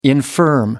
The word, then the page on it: infirm: American English